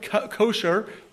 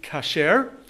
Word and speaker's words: kosher: The Hebrew word kosher, or kosher, is an Americanized Hebrew Ashkenazic pronunciation. Kosher is the way you'd say it in Ashkenazic pronunciation. In Sephardic, it would be kasher.